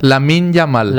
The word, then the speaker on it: Lamine Yamal: Lamine Yamal.